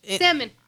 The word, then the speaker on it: salmon: Salmon. Why do you not pronounce that L? – I don't know.